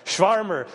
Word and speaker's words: Schwärmer: The German word for enthusiasm that Luther used was Schwärmer. Am I pronouncing that right? Yeah. Schwärmer.